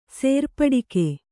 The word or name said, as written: ಸೇರ್ಪಡಿಕೆ